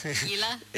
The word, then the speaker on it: Gila: I called it Gila. – Gila? – It's Gila. So, anyway, I had that wrong.